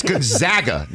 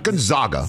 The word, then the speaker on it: Gonzaga: It's Gonzaga, not Gonzaga.